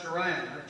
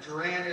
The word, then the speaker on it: Charan: The God of the glory appeared unto our father Abraham when he was in Mesopotamia, before he dwelt in Charan. Charan is just a New Testament rendition or pronouncement of Haran. Over there where it's spelled H-A-R-A-N.